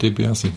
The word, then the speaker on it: DiBiase: DiBiase, ist das richtig ausgesprochen? Oder? – Naja, ah Ted DiBiase.